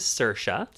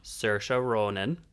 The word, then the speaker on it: Saoirse: So this one is Saoirse. – Saoirse. – Saoirse Ronan.